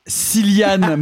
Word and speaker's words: Cillian: Euh, Openheimer de Christopher Nolan avec Cillian Murphy.